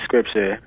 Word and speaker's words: Skrpcha: It's Danny Scripture. The scripture is S-K-R-P-C-H-A. It's not spelled the way you would read it.